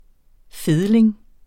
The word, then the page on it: fedling: ˈfeðleŋ